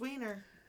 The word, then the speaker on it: Wiener: The love that you asked that question, because State Senator Wiener, am I saying that right? – It's Wiener. – I know how to pronounce it. – 100%. There is no other way to say it.